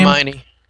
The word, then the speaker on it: Hermione: seen the Harry Potter uh, Hermione? I think that's how you pronounce her name. – Hermione.